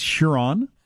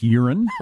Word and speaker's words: Huron: It's Huron. Not pronounced urine.